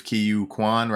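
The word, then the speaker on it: Ke Huy Quan: But like you said, the addition of Kiyu Kwan, right? Did I pronounce that right? – Yeah. Okay. Nailed it, buddy.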